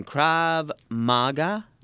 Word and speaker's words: Krav Maga: Krav Maga? – Yeah, that's close.